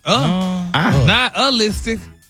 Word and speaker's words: nihilistic: But you actually pronounced it correctly the last time you said it. – Really? Nihilistic? – Wow. – Stop putting the ooh in there. – Nihilistic? – There you go.